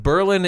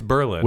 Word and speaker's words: Berlin: Berlin is a city in Wisconsin. – Ugh. – And that's how you say that one.